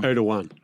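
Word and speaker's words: Erdoğan: Erdogan. – It's got a G. Like, it's a fucking G. – Soft G. – I've heard both. – Very soft G.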